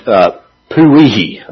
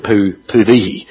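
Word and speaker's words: Powehi: The black hole named Puvihi. I think that's how you pronounce it. Puvihi.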